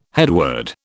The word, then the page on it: headword: British English